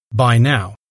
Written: by now

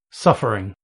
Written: suffering

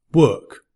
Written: work

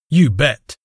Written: you bet

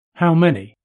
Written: how many

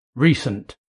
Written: recent

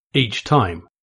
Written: each time

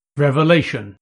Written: revelation

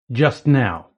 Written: just now

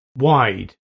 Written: wide

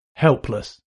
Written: helpless

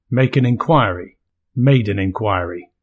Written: make an inquiry

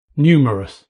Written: numerous

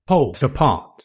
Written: poles apart

